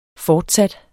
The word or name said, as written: fortsat